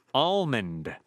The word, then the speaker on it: almond: She writes, "Dear Zorba, you, like many others, pronounce the L in the word almond. But the reason that almond growers do not pronounce the L in almonds is that a person has to beat the L out of them to get them out of the tree."